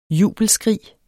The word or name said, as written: jubelskrig